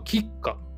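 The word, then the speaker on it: KitKat: 英語での発音はキッカっていうんだ。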